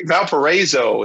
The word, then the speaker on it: Valparaiso: Valparaiso. It's like Valparaiso. – A long A sound.